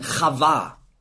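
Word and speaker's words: Chava (חַוָּה): Und jetzt gibt er ihr einen neuen Namen, Eva, hebräisch ausgesprochen Chava.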